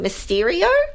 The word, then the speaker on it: Mysterio: As, am I going to say it wrong, Mysterio? – No, that's right.